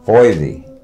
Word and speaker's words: Foisy: My full name, A-I-M-E, Amy Foisy. F-O-I-S-Y, Foisy.